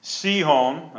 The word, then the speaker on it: Sihon: Verse 4, and he had slain Sihon, that's how you pronounce it, I actually looked that up, the king of the Amorites, which dwelt in Heshbon, and Og.